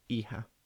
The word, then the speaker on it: hija: Hijo and hija.